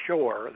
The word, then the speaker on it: Scheuer: Michael Shearer, who I mentioned, his name is spelled S-C-H-E-U-E-R, or Shore, that would be more likely.